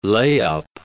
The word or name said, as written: layup